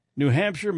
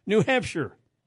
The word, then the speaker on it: New Hampshire: Representative Earl uh, Eric Gallager, a Democrat from Concord or Concord, also wants to include an official pronunciation of New Hampshire in state law to make it clear that shire rhymes with fur, not fire.